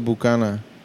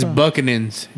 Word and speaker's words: Buchanan's: It's I don't think you pronounce it Bucana. – It's Buchanan's. James Buchanan's.